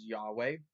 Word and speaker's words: Yahweh: His name, his personal name is Yahweh. That's how we pronounce it.